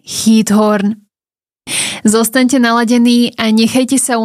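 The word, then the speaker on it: Giethoorn: Za jeden z najpokojnejších kútov Európy môžeme považovať mestečko, dedinku, ktorá podľa toho, ako som sa dočítala, sa vyslovuje Heathorn, alebo vraj po slovensky to niekedy je aj Githorn.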